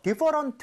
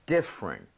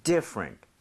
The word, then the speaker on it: different: 이 different 할때 많은 분들이 강세를 잘못 줘서 different 사음절처럼 발음을 하시더라고요. 근데 그게 아니라 아예 강세를 꽝 찍고요. – 네. – 거의 T는 흡수되는 느낌이에요. 이런 느낌이죠. different. different.